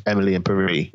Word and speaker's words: Emily in Paris: And like you, I only recently found out that it's meant to be pronounced Emily in Paris. It's meant to rhyme.